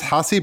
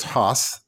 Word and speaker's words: Haas: All right, my guy this week is Tigers catcher Eric Haas, which looks like it may be pronounced Hossie, but it's Haas.